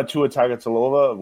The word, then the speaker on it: Tua Tagovailoa: Tua Tagovailoa, whatever however you pronounce that.